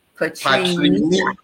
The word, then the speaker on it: Patrina: It's my mother put an extra syllable in my name, so it's Patrina. – Patrina.